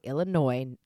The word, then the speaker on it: Illinois: Illinois, the S is silent.